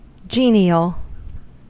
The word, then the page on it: genial: American English